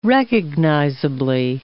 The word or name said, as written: recognizably